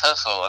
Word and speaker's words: Hussle: But anyway, this Nipsey Tussle, I still can't get his name right. – It's Hussle. It's like Hussle and Float.